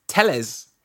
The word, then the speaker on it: Telles: and Alex Telles, which is just pronounced Telles, which is helpful. – With a bit more. Telles, I think.